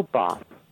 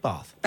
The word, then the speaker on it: Bath: I know Bath. Or Bath. How do you pronounce it? – Bath. – Bath. – How do you pronounce scones? – Bath. – Or Bath. – Bath. – Scones or scones? – Bath. It's Bath.